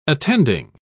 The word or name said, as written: attending